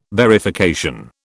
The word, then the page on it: verification: British English